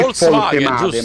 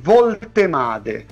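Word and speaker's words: Woltemade: La tendenza è sempre a complicarlo un po', perché in tedesco c'è la V semplice che si pronuncia F, ma la doppia V si pronuncia V. – Come lui non è Voltemade, ma è Voltemade.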